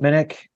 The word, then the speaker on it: Minnich: I can be found on Twitter at Chip Minnick. Last name is spelled M-I-N-N-I-C-H.